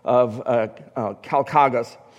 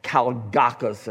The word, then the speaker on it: Calgacus: and he tells the story of Calchagas, or Calgacus, it can be pronounced also, who's a chieftain of the Caledonians up in northern Britain.